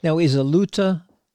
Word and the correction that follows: lauta: tuned like a regular guitar? – It's pronounced lauta. – Oh, lauta. I'm sorry.